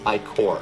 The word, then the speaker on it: Ichor: Alien I Ichor. Ichor. Or Ichor. It's I C H O R.